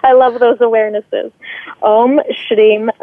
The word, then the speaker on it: Shrim: So the mantra itself sounds like this: Om Shrim Lakshmiye Namaha.